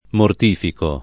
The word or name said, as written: mortificare